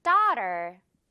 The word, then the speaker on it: daughter: She has a young daughter. 아주 잘했어요, 여러분. 큰 소리로 잘 따라했습니다. 이 AUGHT 발음, 맛있는 떡 발음 확실하게 우리 정복할 수 있겠죠?